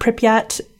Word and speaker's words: Pripyat: that the nearby town of Pripyat, I'm not sure if I pronounced that correctly. Is that correct? – I think that is correct.